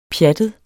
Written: pjattet

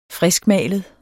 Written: friskmalet

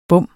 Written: bom

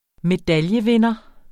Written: medaljevinder

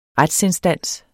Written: retsinstans

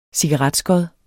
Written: cigaretskod

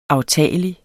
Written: aftagelig

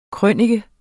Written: krønike

